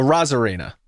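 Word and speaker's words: Arozarena: So you took off the A, and it's not. It's a Rosarena. That's how you say it.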